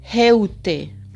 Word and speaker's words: heute: O como en Heute, que sería heute, que es hoy. Entonces, se habrán fijado, la EU no se pronuncia EU en alemán, sino hoy. ¿Vale? Hoy. Hoy, Europa y heute.